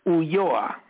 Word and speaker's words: Ulloa: Did I say that right? – That's close. Ulloa.